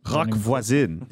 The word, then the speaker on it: Roch Voisine: Uh, Julian, would you rather attend a, a Roch Voisine concert? Is that how I say it? Roch Voisine.